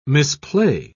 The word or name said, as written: misplay